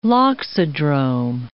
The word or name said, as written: loxodrome